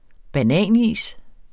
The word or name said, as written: bananis